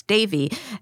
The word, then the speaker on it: Devi: Some people who are listening to this might be like, you wrote this book, why don't you know how to pronounce Devi? Devi is how you pronounce it in Sanskrit, but she wasn't Indian and was widely known as Indra Devi.